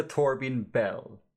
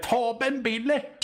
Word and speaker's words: Torben Bille: Alltså inte Torbin Bell. Inte Nej. Jigsaw. – Nej, precis. Utan det är väl Torben Bille. Torben Bille!